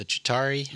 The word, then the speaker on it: Chitauri: and we are greeted by the Chitauri.